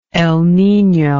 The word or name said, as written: El Nino